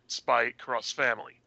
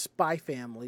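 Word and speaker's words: Spy x Family: and more recent things like Spy Cross Family. – I think that might be just Spy Family, because sometimes the X is silent. – Sometimes it's pronounced X, sometimes it's pronounced cross, but yeah.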